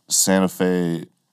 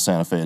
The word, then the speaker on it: Santa Fe: Santa Fe, Nebraska. – Uh-huh. – And they're like, oh, it's not Santa Fe, it's Santa Fe. I'm like, it's spelled Santa Fe. They're like, yeah, that's how we say it.